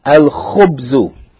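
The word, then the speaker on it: al-khubzu (الخبز): Al-khubzu. This word, khubz, begins with the letter kha. It's from al-huruf al-qamariya, so the lam is pronounced.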